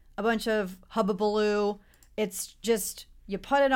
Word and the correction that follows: hullabaloo: It's Did you say hullabaloo? – Yeah. Is that what I said? I didn't say it properly. I said hubbubaloo. Is it hullabaloo? – Hullabaloo. Wait. – Hullabaloo. I said hubbubaloo. – I think it's hullabaloo, right? – Okay, whatever.